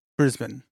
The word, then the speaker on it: Brisbane: See, now, from around here, we would probably say Brisbane, something like that. – Yes, yes. It's pronounced Brisbane.